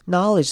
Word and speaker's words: knowledge: Knowledge 这个字要特别注意的是它的中间的 e，也就是 knowle 的那个 e 要念成短母音 i 的声音。 Knowledge 而不是 knowledge，所以要念成 it。